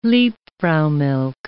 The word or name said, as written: liebfraumilch